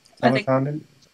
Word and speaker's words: Talokan: All of the Talokan, how do you say that? – Talokan. – Talokians. – Yeah, it's just the Talokan.